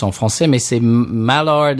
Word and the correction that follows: Maillard: euh, Maillard. Bah, on dirait que c'est, on dirait Maillard en français.